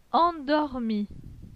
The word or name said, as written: Endormi